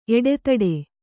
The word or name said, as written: ಎಡೆತಿಟ್ಟು